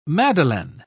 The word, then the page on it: madeleine: British English